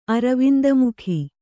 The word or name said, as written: ಅರವಿಂದಮುಖಿ